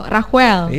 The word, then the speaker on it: Raquel: Q-nya enggak kedengaran. – Oh, Raquel.